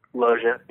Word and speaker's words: Loggia: Robert Loggia, or is it Loggia? – Loggia. Yes. – Loggia.